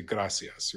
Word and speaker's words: gracias: I have a friend of mine who's from Spain, so he always pronounces that C in the middle as a TH. Our American Spanish or a New World Spanish would say gracias, right?